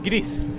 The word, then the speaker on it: Gris: I am the lead animator for Greece. – Is it pronounced Gris or Greece? – It's pronounced actually like Gris, which is Spanish. – Okay. – It's Spanish for gray.